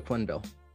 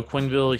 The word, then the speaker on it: Quenneville: Joe Quenneville. – Yeah, Joe Quenneville, he's now the head coach of the Florida Panthers.